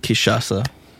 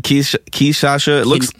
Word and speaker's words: Kinshasa: I said Kishasa. When you spell it, it looks like Kinshasa.